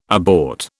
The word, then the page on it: abort: British English